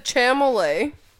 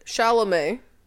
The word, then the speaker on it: Chalamet: You said chamolet. – And what is it? – Chalamet. – And what did I say? You're switching the L and the M.